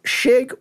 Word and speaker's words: Chig: So they actually probably pronounced it righter than every person that knows me does because people just went Chig because, you know, that's how it's spelled. So Shig would work, but we're sticking with Chig because that's just what people call me.